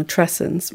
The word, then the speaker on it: matrescence: And matrescence rhymes with adolescence for a reason.